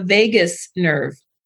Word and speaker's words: vagus: So we have in our bodies something called the vagus nerve, which is spelled V-A-G-U-S, not like Las Vegas.